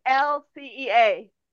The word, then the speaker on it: Mihalcea: My name is spelled wrong here. It's Mihalcea.